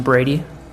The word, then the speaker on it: Brady: Ryan Brady. – Could you spell that? – Ryan, R-Y-A-N, Brady, B-R-A-D-Y.